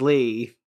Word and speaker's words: Leigh: Do you guys know how you say this guy's name? Lee? – It is Lee.